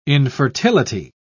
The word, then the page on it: infertility: British English